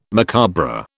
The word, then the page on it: macabre: British English